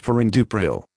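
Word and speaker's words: perindopril: This is the consumer medicine information for Prexam 2.5, Prexam 5, Prexam 10, Pirindopril, pronounced Pirindupril.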